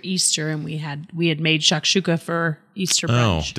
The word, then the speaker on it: shakshuka: So we didn't ever talk about your shakshuka or shakshuka.